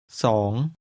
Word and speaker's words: สอง: ผมขอเบียร์สองขวดครับสองขวดสองสองขวด Two is pronounced with a high level tone.